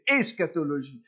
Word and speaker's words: eschatologie: il faut bien le prononcer, eschatologie.